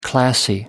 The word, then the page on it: classy: American English